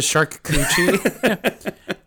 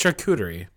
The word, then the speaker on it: charcuterie: The shark coochie. Give me that there shark coochie. – Wait, how do you say it though? – Charcuterie. – I don't know. – It's charcuterie.